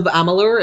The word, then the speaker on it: Amalur: But he also mentions Kingdoms of Amalur.